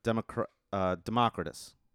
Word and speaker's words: Democritus: Yeah, Democritus is how you pronounce his name.